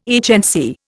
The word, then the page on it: HNC: Indian